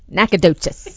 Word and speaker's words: Nacogdoches: Nacogdoches.